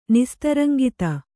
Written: ನಿಸ್ತರಂಗಿತ

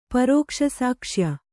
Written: ಪರೋಕ್ಷಸಾಕ್ಷ್ಯ

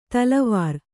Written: ತಲವಾರ್

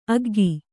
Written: ಆಗ್ಗೆ

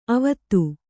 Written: ಅವತ್ತು

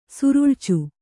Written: ಸುರುಳ್ಚು

